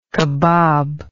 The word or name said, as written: cabob